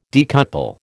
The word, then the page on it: decouple: American English